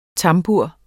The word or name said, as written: tambur